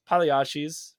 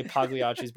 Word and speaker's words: Pagliacci's: But other than teriyaki, man, we got places like this might be a little controversial, maybe not, Pagliacci's. People like to say Pagliacci's, bro. The G is silent. I'm sorry, Pagliacci's.